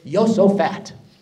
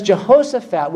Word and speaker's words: Jehoshaphat: And the reason we laughed is because the true pronunciation of Jehoshaphat is Yosophat.